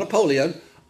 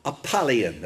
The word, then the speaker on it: Apollyon: Not Apuleium. Apollyon, that's how he's pronounced it.